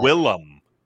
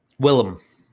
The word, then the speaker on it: Willem: When's the last time you saw Willem? – Willem. – Okay, that's I always say that wrong.